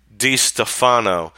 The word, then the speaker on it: DeStefano: And I think I said Stefano in the live short I did yesterday, but it's John DeStefano that is in charge of this personnel situation.